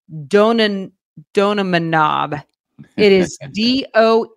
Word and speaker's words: donanemab: D-O-N-A-N-E-M-A-B. – Like you said it, but faster.